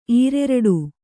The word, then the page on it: ಈರೆರಡು: Kannada